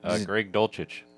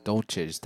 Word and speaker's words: Dulcich: Greg Dulcich. – Dulcich, thank you.